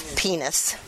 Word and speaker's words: Pinus: penis. It's a long I, right? P-I-N-U-S. It's pronounced penis.